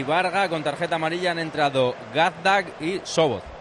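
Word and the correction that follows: Csoboth: ¿Cómo se pronuncia Sobot? A ver. – Kevin Chobot. – Chobot, porque Chobot. Es una CS antes.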